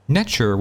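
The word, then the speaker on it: netjer: Netcher was actually pronounced extremely close to the English pronunciation of nature.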